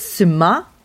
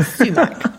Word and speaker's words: sumac: It's suma, the emphasis on the second syllable, and you're not pronouncing the C. So no more are we going to talk about sumac.